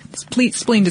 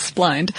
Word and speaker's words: splined: Spleened is probably not the correct pronunciation there. I'm going to assume it's splined directly to the shaft.